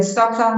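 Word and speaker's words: stokvel: Yeah, and Stockfell in this case is spelled Stockvel. Again, Stockvel.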